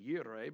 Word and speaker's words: Jireh: More properly pronounced Yireh, but it's Jehovah Jireh goes better with the song. Right? Jehovah Jireh, my provider. Jehovah Yireh, my provider. It don't rhyme as good, so.